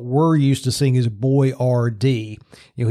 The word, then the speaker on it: Boiardi: His name is spelled B O I A R D I, Boyardee.